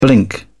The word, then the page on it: blink: British English